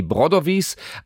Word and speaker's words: Brodovi: Brodovis, aber wir sind dann irgendwann zum Brodovi übergegangen mit Betonung auf erster Silbe, weil Brodovi, letzten Endes, das habe ich in der Schule zu oft gehört, ist einfach doof.